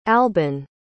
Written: albin

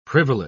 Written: privileged

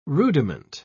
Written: rudiment

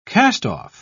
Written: castoff

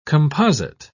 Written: composite

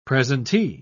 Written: presentee

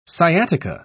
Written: sciatica

